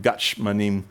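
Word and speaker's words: Gatshmanim (גת שמנים): Gethsemane, we pronounce it Gatshmanim in Hebrew, the place of the olive press.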